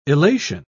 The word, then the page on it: illation: British English